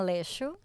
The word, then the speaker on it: Aleixo: Marina Aleixo, but that works. That works. – Aleixo? – Uh-huh. – Okay, that sounds much better than my pronunciation.